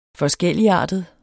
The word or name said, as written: FORSKELLIGARTEDE